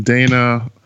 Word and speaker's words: Dayna: And Dayna, my dad did some interesting things to the spelling of my first name.